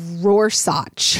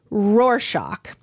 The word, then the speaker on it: Rorschach: like that in Brookline, Massachusetts, and my sister-in-law, Susan, came up to me afterward and she pointed out that I had been saying Rorschach wrong. Guys, Matthew and listeners, I had said this wrong in front of hundreds of people. – What were you saying? – I was saying Rorschach. and I mean, I'd written an entire book that used this word and had never known how to properly pronounce it. And I still, every time I see it, I want to say Rorschach. – Yeah, no, that makes sense. – But it's Rorschach.